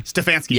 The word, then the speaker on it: Stefanski: Stefanski.